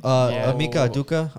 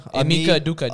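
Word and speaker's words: Ime Udoka: Amika Aduka. – Amika Aduka.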